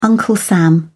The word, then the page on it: uncle sam: British English